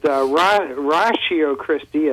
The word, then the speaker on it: Ratio Christi: They see the word ratio, R-A-T-I-O, and pronounce it ratio like... we would in mathematics, I guess, and then we're saying it ratio, and those who know Latin really well sometimes say ratio, but ratio Christi, as we pronounce it,